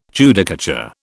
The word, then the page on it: Judicature: British English